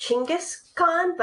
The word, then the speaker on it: Chinggis Khan: So Genghis Khan, it's pronounced, I've heard that historians, it's actually pronounced Chinggis Khan, but I'm gonna go with Genghis Khan because they're the ones.